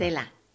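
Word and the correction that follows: Xela: Oh, ya, lo destruyó otra vez, Miriam. Shela. – Shela.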